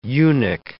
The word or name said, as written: eunuch